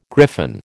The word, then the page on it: griffon: American English